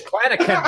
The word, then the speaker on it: Clanachan: We'd like to thank David Klinakin. – Sorry, brother. See, I knew I'd fuck it up. – It's Scottish. Klinakin, like a hard C.